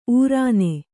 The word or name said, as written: ಉರಣ